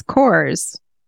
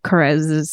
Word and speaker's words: Korres: Okay, so I smell like Kerez, K-O-R-R-E-S, Saffron Orris. – Is that how you say that? It's Kerez? – Yeah. Kerez. I remember because in my head, it's Coors, because that's the English pronunciation.